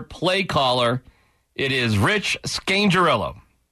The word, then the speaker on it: Scangarello: Scangarello, right? That's how you say it. Right, there's no R, sorry. Scangarello, my apologies.